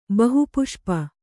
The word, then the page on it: ಬಹುಪುಷ್ಪ: bahu puṣpa